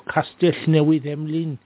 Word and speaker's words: Castellnewydd Emlyn: Newcastle Emlyn, or Castellnewydd Emlyn, for the Welsh pronunciation, and near the Cardiganshire coast.